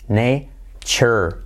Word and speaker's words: nature: El error típico es decir nature, nature, nature, nature, y no es nature, chur, chur, chur.